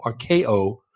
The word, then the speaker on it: arkeo: Okay, the word content in verse 8 of 1 Timothy 6 is the Greek verb archeo. It's spelled A-R-K-E-O, archeo, which means to be satisfied, to be sufficient, or to be enough.